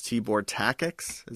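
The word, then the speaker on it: Tibor Takács: Tibor Takacs, is that right? – Sounds right.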